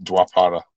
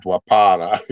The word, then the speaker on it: Dwapara: If you can't pronounce it, makes me think I can't. – Well, just it's Dwapara. – Dwapara Yuga. – Yeah, Dwapara.